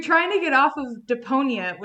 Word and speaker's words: Deponia: Am I saying that right? – Yeah, Deponia. I don't know.